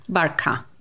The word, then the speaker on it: Barkha: Barkha.